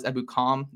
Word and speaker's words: Ebukam: Samson Ebukam, who I think I'm saying that right now is Ebukam, is how you say his last name.